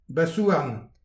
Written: bazh-houarn

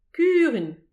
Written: kurun

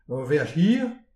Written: ur veaj hir

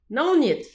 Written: naoniet

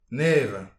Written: nevez

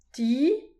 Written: ti

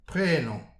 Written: prenan

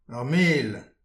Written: ar mel

